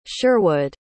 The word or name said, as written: Sherwood